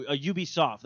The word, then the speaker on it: Ubisoft: I kept saying UBI Software, and everyone's all like, no, Ubisoft. That's what I used to say. And everyone's like, why is it calling Ubisoft?